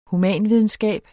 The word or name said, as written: humanvidenskab